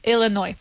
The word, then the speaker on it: Illinois: We do write it Illinois. So we spell it Illinois. All right. But we don't say Illinois. We say Illinois. No S sound. Illinois.